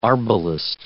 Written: arbalist

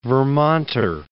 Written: Vermonter

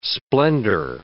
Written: splendor

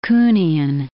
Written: Kuhnian

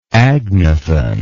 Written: agnathan

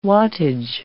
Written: wattage

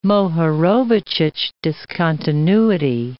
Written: Mohorovicic discontinuity

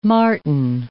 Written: martin

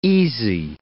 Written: easy

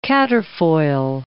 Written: quatrefoil

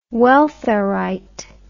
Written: welfarite

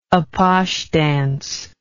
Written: apache dance

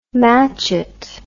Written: matchet

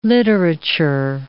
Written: literature

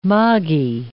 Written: moggy

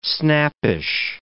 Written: snappish

